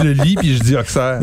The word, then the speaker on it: Auxerre: On a ici un côte d'Auxerre, donc des D-A-U-X-E-R-R-E. E e. – Donc, la ville d'Auxerre. Mais c'est ben difficile. On, Auxerre. Mais c'est Auxerre. En fait, tous les X sont, sont, sont souvent prononcés en S.